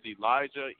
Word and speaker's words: Elijah: Is Elijah E-L-I-J-A-H?